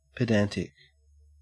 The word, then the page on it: pedantic: Australian English